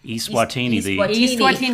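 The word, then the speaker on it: Eswatini: When you say when you were in is it Estuani? – East Swatini, because I butchered that when I introduced you.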